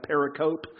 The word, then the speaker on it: pericope: It's called pericope, P-E-R-I-C-O-P-E. Looks like pericope. It's pronounced pericope.